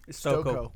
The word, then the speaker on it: Stokoe: Stokoe.